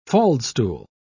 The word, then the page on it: faldstool: British English